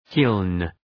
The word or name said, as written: kiln